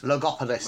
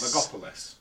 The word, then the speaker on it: Logopolis: And then for Elle, she's gone for, and I don't know how to pronounce this, Logopolis? Logolopis? – Logopolis. – Logopolis.